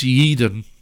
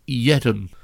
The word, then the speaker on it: Yeadon: It's pronounced Yeadon, not Yeadon.